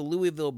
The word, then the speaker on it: Louisville: After serving with the Louisville Brigade in the 1840s, Weller returned to Louisville to open his...